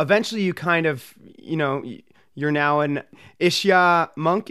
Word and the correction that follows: Ishaya: Did I pronounce that right? Almost Ishaya. Ishaya.